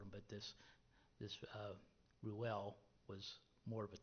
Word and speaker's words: Reuel: I keep forgetting how to pronounce it properly. Reuel. There we go.